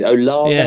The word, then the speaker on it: Olave: They're missing a couple of guys with Michael Thomas and Olave's out as well. I can't remember how you pronounce this. Olave.